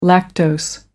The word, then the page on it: lactose: American English